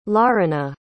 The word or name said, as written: larina